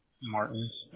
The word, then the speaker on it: Martins: Martin, M-A-R-T-I-N-S. – Okay. And do you spell it J-A-M-A-R or J-O-M-A-R? – J-O. – M-A-R.